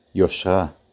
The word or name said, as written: יושרה